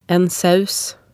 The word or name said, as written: en saus